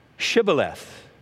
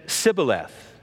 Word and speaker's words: Shibboleth: They couldn't say the S-H, Shibboleth. They could say Sibboleth.